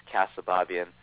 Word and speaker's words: Keosababian: And uh, my last name is Keosababian.